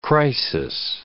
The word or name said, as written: crisis